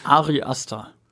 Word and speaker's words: Ari Aster: Ari Aster.